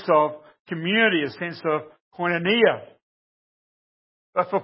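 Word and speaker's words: koinonia (κοινωνία): And this is, well, some of us men learned yesterday, the word for fellowship here is koinonia. Or koinonia, if you pronounce it correctly. Got it right, Mark, I hope? Koinonia, yes.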